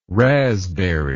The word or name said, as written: razzberry